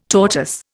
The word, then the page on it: tortoise: Indian